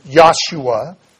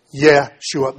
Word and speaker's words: Yeshua: In the English form, this is Yahshua, Joshua, where you get more of an ah sound. But the way Hebrew is written and the general pronunciation is Yeshua. Yeshua.